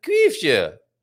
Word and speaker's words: Kuifje: So to recap, Tintin is called Tim und Struppi in Germany and Kuifje in Amsterdam. And you do have to say it like that.